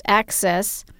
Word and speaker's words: AHCCCS: The Arizona Healthcare Cost Containment System, or AHCCCS, pronounced ACCESS, offers health insurance through Kids Care.